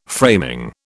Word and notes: framing: British English

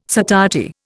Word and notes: satiety: Indian

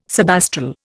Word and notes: subastral: Indian

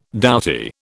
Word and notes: doughty: British English

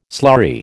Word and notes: slurry: American English

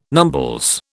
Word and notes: numbles: British English

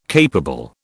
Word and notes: capable: British English

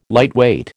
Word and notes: lightweight: American English

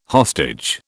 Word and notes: hostage: British English

